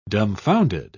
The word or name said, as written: dumbfounded